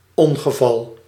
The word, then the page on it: ongeval: /ɔnɣəvɑl/; /ɔn.ɣǝ.vɑl/